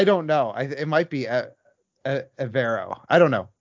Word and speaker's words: Evero: I was Googling something, because I can never pronounce the defensive coordinator's name for the Broncos. Ejiro Avero. – Avero. Okay. Yeah. I thought it was Evero.